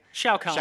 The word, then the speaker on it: Shao Kahn: Shao Kahn. – Shao Kahn. – Raiden, it's like Shere Khan, but you say Shao instead.